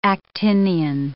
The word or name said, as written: actinian